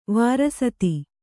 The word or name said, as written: ವಾರಸತಿ